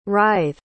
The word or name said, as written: writh